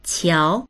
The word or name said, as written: qiao2